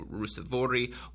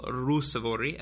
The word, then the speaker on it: Ruusuvuori: Ruusuvuori one-on-one. I should have said Emil Ruusuvuori, as I learned how to pronounce.